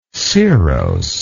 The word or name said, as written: cirrose